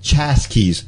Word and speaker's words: Chasquis: Chaskis. That is spelled Chasquis. Chaskis.